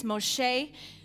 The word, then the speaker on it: Moshe: If you go back to what now I'm learning how to say their names right, you know, we learned Moses, it's Moshe. Abraham, it's Avraham.